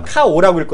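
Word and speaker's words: 카오 (花王): 일본말을 보면 한자로 화왕이라고 쓰는 거 있어요. 일본말로 카오라고 읽거든요, 카오? – 네.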